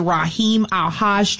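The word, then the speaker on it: Rahim AlHaj: We also have on the list is Raheem Alhaj Trio. Did I say that right, Blaine? – You nailed it.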